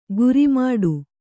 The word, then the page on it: ಗುರಿಮಾಡು: gurimāḍu